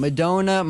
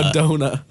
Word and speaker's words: Madonna: Madonna. – Madonna. – He's crying. He is crying laughing. – I saw the double N and I was... – No, okay. Madonna would be like M-E-D-O-N-A. Madonna. Madonna would be like D-O-N-N-A.